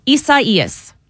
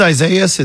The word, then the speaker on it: Isaias: How do you pronounce this storm's name? – I know. Bless it. It is Isaias. – I'm running it out phonetically so I can pronounce it later. – It's Isaias in Spanish. – Isaias. – Yeah, but the National Hurricane Center decided to go with the Spanish pronunciation.